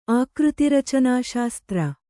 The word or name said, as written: ಆಕೃತಿರಚನಾಶಾಸ್ತ್ರ